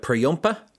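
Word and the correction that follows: Jhumpa: Jumpa Lahiri, whereabouts? Oh, yeah. Is that the one? – Yeah. No, I got it wrong. Jumpa Lahiri.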